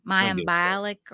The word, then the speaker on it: Mayim Bialik: Mayim Bialik or whatever.